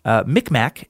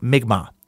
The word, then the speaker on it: Mi'kmaq: Also, side note, if it hasn't been mentioned by someone else, Micmac is actually pronounced more like Mi'kmaq.